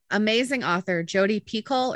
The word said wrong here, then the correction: Picoult: or Picou. Picoult. I never knew how to say her last name, but Jodi Picoult is great, great author.